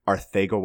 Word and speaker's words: Arcega: Arcega Whiteside. Yeah, I mean, it's how you technically pronounce the sus sound in Spanish, well, in, like traditional Spanish.